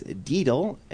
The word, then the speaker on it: daedal: The word of the day, and this is kind of a fun word, is deedle, and that's spelled D-A-E-D-A-L.